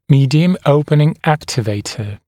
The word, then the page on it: medium opening activator: ‘miːdɪəm ‘əupənɪŋ ‘æktɪveɪtə; ‘ми:диэм ‘оупэнин ‘эктивэйтэ